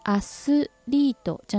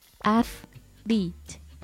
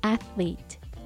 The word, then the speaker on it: athlete: th の音が入っているのでアスリートじゃなくてアスリートアスリート上下の歯の間に舌を挟みながら空気を漏らすようなイメージで発音します